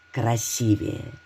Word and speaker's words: красивее: Часто все ставят ударение на предпоследний слог «красивее», что неправильно. Здесь действует правило. В слове «красивее» ударение ставится не так, как в других прилагательных.